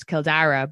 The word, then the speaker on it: Kildare: And Dublin is like Blackpool or like I'm from Kildare, which is Kildara. But when the Brits pronounce it, it's Kildare. So I'd like to think I have like a white trash American spelling of Sean.